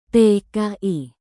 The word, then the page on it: tki: Indonesian